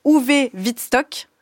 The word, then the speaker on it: Uwe Wittstock: Votre traduction de Marseille 1940, quand la littérature s'évade, de Uwe Wittstock. Je le prononce bien ? – Tout à fait.